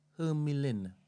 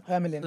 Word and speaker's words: Hermelin: All right, so it's pronounced Hermelin. – Huh? – Hermelin. – Hermelin, yeah. Hermelin. – What is? What was that one? – H-E-R-M-E-L-I-N. H-E-R-M-E-L-I-N, yeah.